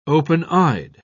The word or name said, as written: open-eyed